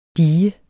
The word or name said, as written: DIGERNE